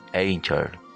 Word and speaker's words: Anchor: encuentra como siempre en esta aplicación, como se dice en la pronunciación en español, Anchor, pero en inglés se dice Anchor.